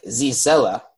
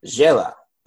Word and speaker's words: Zsela: It is spelled Z-S-E-L-A. So it looks like Zizella, but it's pronounced Jayla.